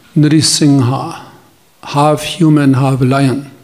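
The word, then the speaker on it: Narasimha: You have to pronounce Narsingha properly. – Narsingha musíme vyslovovat správně. – Many devotees pronounce Narsimha. – Mnoho daných říká Narsimha. – There's no Shimha in Sanskrit. – V sanskritu žádné Shimha není. – Lion means in Sanskrit Simha.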